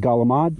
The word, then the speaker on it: calamari: But they pronounce it Galamad, right? Although it's spelled calamari, right?